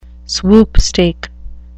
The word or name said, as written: swoopstake